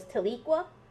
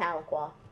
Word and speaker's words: Tahlequah: Like, I always thought it was Tahlequah. It's Tahlequah.